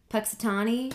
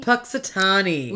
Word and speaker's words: Punxsutawney: Punxsutawney. – Punxsutawney.